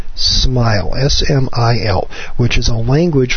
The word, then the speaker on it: SMIL: In fact, the top hit is Synchronized Multimedia Integration Language, spelled SMIL, pronounced SMILE.